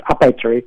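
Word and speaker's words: Apetri: And I was just about to say that it's an unusually good pronunciation. You know, I usually don't get it so good in the United States. So it's a Petri, if you want to talk. It's very much Italian.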